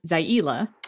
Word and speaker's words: Zaila: Her name is pronounced Zaila.